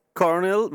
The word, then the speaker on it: Colonel: Si dice Cornell, ma si scrive Colonel.